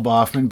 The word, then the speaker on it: Baughman: Boffman, B-A-U-G-H-M-A-N.